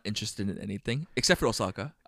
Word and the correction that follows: Ahsoka: Nope. How do you pronounce her name? – Ahsoka with Rosario Dawson.